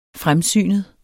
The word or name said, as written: FREMSYNEDE